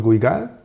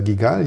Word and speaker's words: Guigal: Es ist ein Château Ampouilly von Guigal oder Guigal, je nachdem, wie man es gerne aussprechen möchte, aus dem Jahr 2000.